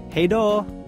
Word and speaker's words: hej då: Hej då.